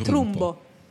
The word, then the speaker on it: Trumbo: Questa cosa è stata fatta solamente con questo Trumpo, si pronuncia, penso. Donald Trumpo. – Non è Donald, è Dalton Trumpo. – Dalton Trumpo. – Trumbo.